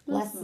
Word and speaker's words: Muslim: And I think particularly when it comes to Muslim Did I say that right? I'm always scared that I'm going to say it like the wrong way. Muslim, right? Because yeah. there's a Muslim. Wrong way. Muslim. – Yeah. Muslim. S. Muslim. Less Z. More Muslim. S.